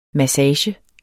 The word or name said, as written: MASSAGERNE